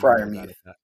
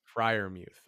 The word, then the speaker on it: Freiermuth: Freiermuth. – How is that? – Freiermuth. – Freiermuth.